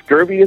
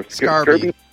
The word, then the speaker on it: Scarbee: I will have to check out the you pronounce it Scurvy, is it? Or Scarby. – Scarby. Scarby. – Mm-hmm. S-C-A-R-B-E-E.